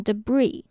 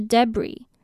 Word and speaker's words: debris: 那在法文的规则里面，通常子音在后面都是不发音的，所以它被沿用至英文的时候，它的 s 也一样是不发音，就变成 debris，或者是另一种念法是 debris，其实都可以。